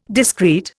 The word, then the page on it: discreet: Indian